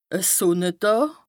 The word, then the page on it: e Sùnnedàà: Bas-Rhin; Strasbourg